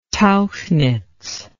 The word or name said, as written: tauchnitz